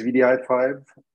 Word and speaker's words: wdi5: And it's spelled VDI5, even though it's written WDI5 for the very sole reason that it's very awkward to pronounce WDI5 all the time.